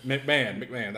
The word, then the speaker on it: McMahon: McMahon, that's how you say it.